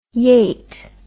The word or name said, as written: yate